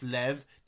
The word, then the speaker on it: Lev: They, they, they see that name and they're like Liu, and even when even when they know it's Lev, they will still s- spell it with a.